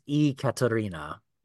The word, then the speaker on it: Ekaterina: I think her name is pronounced Ekaterina.